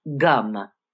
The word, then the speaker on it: Gam: Now you notice it's spelled G-A-M, and it's spelled G-A-N-A-P-D, but it's gum.